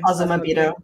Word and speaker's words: Azumabito: Azumabito, is that how you say it? – Azumabito.